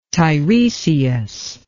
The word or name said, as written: Teiresias